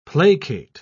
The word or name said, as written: placate